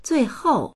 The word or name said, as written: zui4hou4